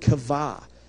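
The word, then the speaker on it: kavah (קָוָה): The Hebrew word for wait is the word kavah. Q-A-V-A-H is how we would spell it in English.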